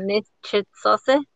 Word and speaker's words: Níłch'its'ósí: and it's um Níłch'its'ósí, I, I believe I'm pronouncing that right. Is that right, Bookie? Yeah. Yep.